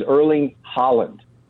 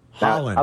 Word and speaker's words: Haaland: As far as I know, and listening to the man himself, the way you pronounce City's striker's name is Erling Haaland. – Haaland. – Abdallah says I'm wrong. He says he has sources in his country that say that is not how it's pronounced. I told him that is how he pronounces it.